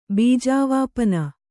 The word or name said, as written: ಬೀಜಾವಾಪನ